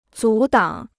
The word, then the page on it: 阻挡: zǔ dǎng